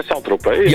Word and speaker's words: Saint-Tropez: Ja, Saint-Tropez.